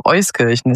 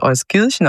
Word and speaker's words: Euskirchen: In Euskirchen, und die Betonung liegt tatsächlich auf Euskirchen. Es wird sehr gerne oft Euskirchen ausgesprochen, aber es ist Euskirchen.